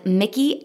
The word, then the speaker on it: Miki: So this woman's name was Mickey Agrawal, okay? – Ooh, cool name. – So, and it's spelled M-I-K-I. Like, it's a cool way, okay? – Mickey.